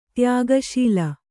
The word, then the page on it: ತ್ಯಾಗಶೀಲ: tyāga śila; kannada